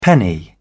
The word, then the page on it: penny: British English